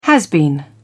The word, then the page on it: has-been: British English